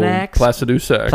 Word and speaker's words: Placidusax: I think Dragonlord Parsonax. – Oh, Placidusax.